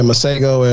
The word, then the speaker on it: Masego: The song is by Masego. Did I pronounce that correctly? – Yeah, Masego and French Kiwi Juice.